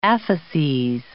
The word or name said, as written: apheses